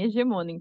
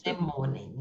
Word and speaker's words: Hegemonen: Mas antes, eu falei certo o nome do santuário? – Falou sim, Hegemonen, perfeito. – Hegemonen, então muito bem.